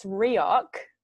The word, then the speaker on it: Reoch: It's Reoch. – Okay. – It's hard Scottish C. in origin. Yeah, it's like a CK, but it's CH.